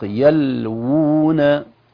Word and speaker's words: يلوون: أيضا في الآية يا أحبتي 78، ومن أهل الكتاب، وإن منهم لفريقا يلوون ألسنتهم بالكتاب، يلوون، سنجد واو في أصل الكلمة وواو بخط صغير، الواو بخط صغير تقوم مقام الواو الأصلية وتنطق يلوون، يلوون، و يعني الواو الأولى واو مضمومة ممدودة، والواو الصغيرة هذه هي الواو المادية، يلوون ألسنتهم بالكتاب.